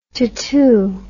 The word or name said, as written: tatou